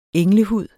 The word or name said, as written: englehud